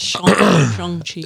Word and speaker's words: Shang-Chi: Why do you keep saying Shang? – The same reason why it says Hong Kong. – Hong Kong, but Shang-Chi. – So that's how you're supposed to pronounce it.